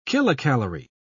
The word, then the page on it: kilocalorie: British English